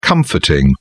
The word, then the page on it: comforting: British English